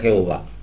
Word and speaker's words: Jehová: y la palabra Yod-Heh-Vav-Heh se pronuncia Jehová.